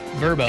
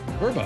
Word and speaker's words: VRBO: Well, I just found out, not too long ago that, what is it, the VRBO? – Verbo. – It's Verbo. I saw this VRBO. – Yeah. – And all of a sudden you hear a commercial, I'm like, oh, Verbo.